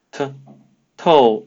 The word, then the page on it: 透: Mandarin Chinese